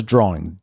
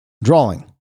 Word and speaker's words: drawing: The word is drawing, drawing. I hear some people say, including myself sometimes, drawing, like there's an L in there somewhere, drawing.